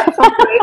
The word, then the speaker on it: feng shui: I hope I'm saying that correctly. Feng shui? – Yeah, feng shui is fine.